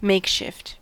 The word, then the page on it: makeshift: American English